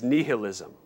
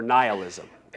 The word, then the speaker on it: nihilism: Then finally, the third general error with regard to truth and how we know it is nihilism or nihilism. Either way is fine in terms of pronunciation.